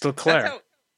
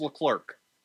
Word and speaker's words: Leclerc: It's Leclerc. That's French. – That's how he pronounces it. No. – Really? – Listen to him pronounce his name. He pronounces his name Charles Leclerc.